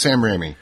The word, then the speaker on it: Raimi: And I always butcher his name. It's Sam Raimi? – Yep. – Raimi? So I think it's Sam Raimi. The correct pronunciation.